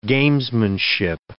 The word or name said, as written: gamesmanship